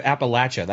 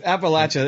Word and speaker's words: Appalachia: So and you said it right. You said Appalachia. That's great. That's exactly how you want to say it. Like, if you hear somebody say Appalachia, like, you're like, that's wrong. You've never been there. It's Appalachia is perfect. That's the right way to say it for sure.